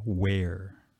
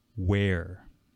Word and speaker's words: where: Where. Where. Okay, it's also the same like when you're wearing clothes. Where. Same pronunciation exactly. W-E-A-R. Okay, so those are pronounced exactly the same.